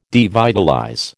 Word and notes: devitalize: American English

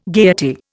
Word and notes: gaiety: Indian

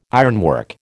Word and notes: ironwork: American English

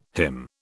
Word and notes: hymn: British English

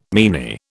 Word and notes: meanie: British English

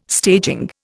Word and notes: staging: Indian

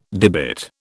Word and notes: debit: British English

